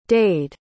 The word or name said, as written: dade